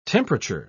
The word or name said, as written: temperature